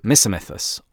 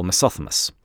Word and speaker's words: Misomythos: And the poem itself is actually addressed from Philomythos, or Philothemus, depending upon your pronunciation, to Misomythos, or Misothemus. Literally, it means from the myth lover to the myth hater.